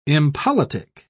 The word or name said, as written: impolitic